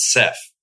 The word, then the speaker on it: Saif: Well, I pronounce it Seth. Rhymes with Jeff.